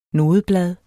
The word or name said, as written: nodeblad